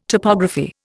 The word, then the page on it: topography: Indian